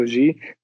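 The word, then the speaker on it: ji: A veľmi, veľmi často sa robí to, že po meno sa pridáva ako G. G ako, po anglicky to sa píše akože J, I, ale vyslovuje sa to G.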